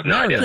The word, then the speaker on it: Mario: It's not Mary with an O. – Yeah, Mario. That's a good point. Yeah, I never really thought about that. How does he say it in the movie? – Mario. – Mario. – Mario. – That's how you pronounce it. That's the correct way.